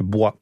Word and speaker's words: Boy: Il y a une autre explication, c'est un nom de famille en Auvergne, et dans la région du Sud-Ouest toujours, qui serait une variante altérée de bois, B-I-B-O-I-S. C'est pour ça qu'on prononce bois. – Combien de soldats bois, B-O-Y, tombés pour la France en 14-18 ?